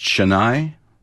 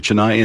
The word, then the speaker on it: Chennai: Is it pronounced Chennai? – Correct. Absolutely. – Is it with a sh or a chi? – Chennai. – Okay, Chennai, India.